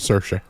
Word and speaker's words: Saoirse: How do you say it? – Saoirse. – Saoirse Ronan.